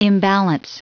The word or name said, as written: imbalance